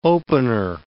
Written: opener

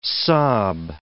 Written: sob

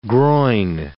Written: groyne

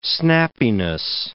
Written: snappiness

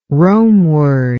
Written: Romeward

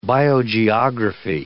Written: biogeography

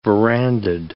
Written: verandahed

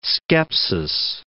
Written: skepsis

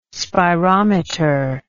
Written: spirometer

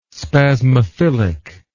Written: spasmophilic

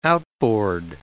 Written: outboard

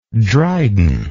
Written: drydenic

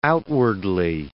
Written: outwardly

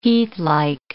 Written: heathlike

